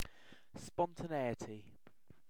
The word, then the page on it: spontaneity: British English